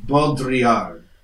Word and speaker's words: Baudrillard: Baudrillard.